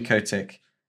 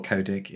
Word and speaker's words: Kotick: If you've been living under a rock, Bobby Kotick, or Kodik, if you're from America, depending on how you like to pronounce his name, he's been a naughty boy, hasn't he, Vin? – Yeah, it's a little bit.